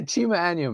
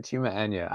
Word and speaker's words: Chima Anya: I notice, okay, Americans always like to put a little E where the A is, but it's like Anya, or Anya, sometimes they say, but Anya, it's all good, bro.